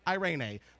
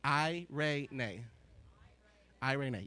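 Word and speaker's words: Irene (εἰρήνη): And the Greek word is spelled E-I-R-E-N-E. So it's like Irene with an E, but you pronounce it Irene. Irene. Like Irene really far away. I ran a really far way. I ran a really far way. So, I ray nay.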